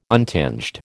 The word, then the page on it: untinged: American English